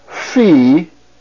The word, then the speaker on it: Phi: The first letter, notice the Greek letter, Phi. That's its letter name, Phi. And it is pronounced like the PH sound, as in the word phone.